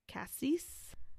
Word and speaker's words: cassis: This cocktail is 1.5 ounces of tequila, 1/4 ounce of creme de cassis. Stop laughing at me. Is it pronounced cassis? – Uh-huh. – Okay. Cassis.